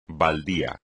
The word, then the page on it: baldío: espagnol